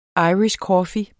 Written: irish coffee

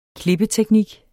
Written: klippeteknik

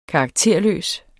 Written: karakterløs

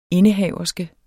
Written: indehaverske

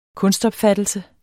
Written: kunstopfattelse

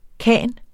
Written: khan

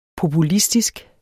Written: populistisk

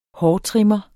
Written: hårtrimmer

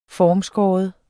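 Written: FORMSKÆRE

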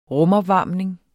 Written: rumopvarmning

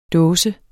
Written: dåse

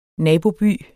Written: nabo

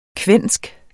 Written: kvensk

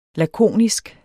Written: lakonisk

